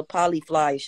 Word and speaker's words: Polyfly: You on the Polly Grammy show? – Shut the hell up. You know the name of the show. – I said it right, Polly Grammy. – No, it's the Polyfly show.